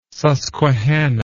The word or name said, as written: susquehanna